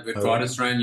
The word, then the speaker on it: Witwatersrand: Yeah, Witwatersrand University.